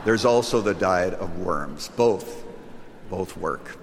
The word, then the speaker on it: Worms: Can't give the background for lack of time, but he's on his way to Worms, Germany. W in German is pronounced like a V. It is Worms.